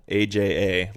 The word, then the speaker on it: Aja: I've never heard of what is that a movie or a band or is playing Asia AJA like the. – It's pronounced Aja, Mike. – No. That's if you're a Steely Dan fan, listen to the song. They say Asia about 400 times in the song.